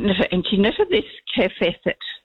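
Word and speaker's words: Kaffe Fassett: Kefacet. – Yeah, yeah. So it's spelled I just wanted to, for everyone, K-A-F-F-E, Kafe, Facet, F-A-S-S-E-T-T.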